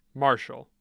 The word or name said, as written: Marshall